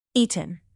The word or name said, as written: eaten